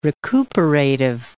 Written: recuperative

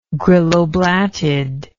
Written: grylloblattid

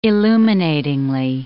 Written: illuminatingly

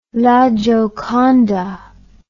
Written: gioconda la

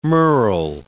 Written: merl

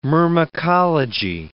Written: myrmecology

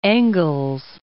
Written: Engels

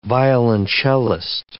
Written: violoncellist

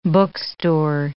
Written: bookstore

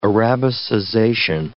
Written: arabicization

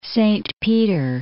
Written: Saint Peter, Lake

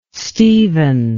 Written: Steven

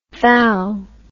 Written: thall

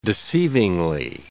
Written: deceivingly

